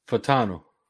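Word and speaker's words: Fautanu: then going out and getting Troy Font, I don't know how to say his name. I'm sorry. How was it again? – Fatano. – Okay. So Troy Fatano.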